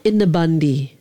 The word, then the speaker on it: innebandy: Is it called innebandy? How do you pronounce it? – That's right. Innebandy. – Oh, it is Innebandy.